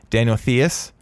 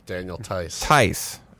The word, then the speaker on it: Daniel Theis: They reacquired, I hope I pronounced this right, Daniel Theis from the Rockets. – Daniel Theis. – Oh, excuse me.